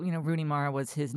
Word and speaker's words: Rooney Mara: And he was talking about Rooney Mara, who I think I just said her name right for the first time in living history. – It is.